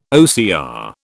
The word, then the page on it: OCR: British English